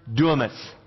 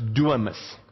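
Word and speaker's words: dunamis (δύναμις): The Greek word for power is spelled D-Y-N-A-M-I-S, and it is pronounced dunamis. Alright? The N is like a silent N, so it's dunamis.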